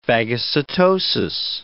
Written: phagocytosis

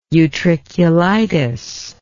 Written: utriculitis

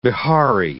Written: Bihari

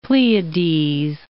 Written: Pleiades

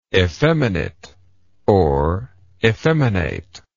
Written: effemination

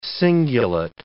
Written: cingulate

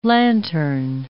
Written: lantern

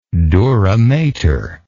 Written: duramater

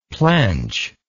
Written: plench

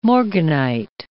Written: morganite

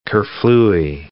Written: kerflooey

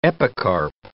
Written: epicarp

